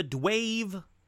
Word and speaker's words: D-Wave: It's called the Dwave Quantum 2, and I didn't say Dave, and I didn't mispronounce Dave. It is D-W-A-V-E Quantum 2.